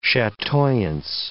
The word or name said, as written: chatoyance